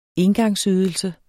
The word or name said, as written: engangsydelse